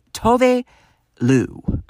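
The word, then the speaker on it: Tove Lo: So, I mean, sure, maybe that's the case, and then maybe I don't feel so bad for thinking that it was Tuvlo all these years, but the actual preferred, like, pronunciation of the singer's name is Tuvi Lou.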